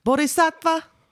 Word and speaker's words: Bodhisattva: I've never known how to say this, but the way they say it is Bodhisattva.